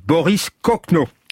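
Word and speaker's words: Boris Kochno: C'est, ça se prononce comme un faux ami, Boris Cochenot.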